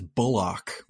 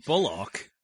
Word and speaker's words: Bullock: He liked that the film didn't stop to explore the relationship between Keanu and Bullock, although he says Bullock, which was quite interesting. – Bullock? – He said the Bullock, like a buttock, you know, like Americans say that.